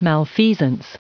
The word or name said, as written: malfeasance